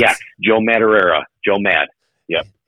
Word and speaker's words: Madureira: but Joe Madureira. Did I say that right? – Joe Madureira? – Yeah, that's